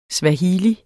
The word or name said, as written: SWAHILI